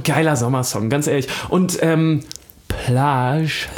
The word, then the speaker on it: Plage: Ich habe es sehr französisch, ja, ausgesprochen. Plage.